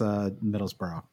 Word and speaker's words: Middlesbrough: So I still can't pronounce Middlesbrough.